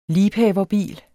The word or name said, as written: LIEBHAVERBIL